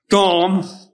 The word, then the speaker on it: dam: I looked at the Hebrew word for blood, and it's pronounced D-A-M, but it's Dom, and it's as in Adam.